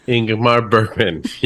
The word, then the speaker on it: Ingmar Bergman: Ingmar Bergman, yes.